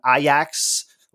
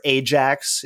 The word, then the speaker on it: Ajax: I don't know if there are any, you know, soccer/football fans in your lives, but have you heard of the Dutch team Ajax? Or Ajax, if you prefer the more anglicized pronunciation.